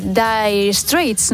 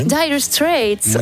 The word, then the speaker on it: Dire Straits: Y también vamos a seguir viajando en nuestra máquina del tiempo esta semana, que es a través de las canciones de Dire Straits.